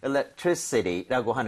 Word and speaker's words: electricity: So, electricity라고 하는 거, I에다 강세를 찍고 이렇게 발음을 하게 되면 전기라는 명사가 될 수가 있습니다.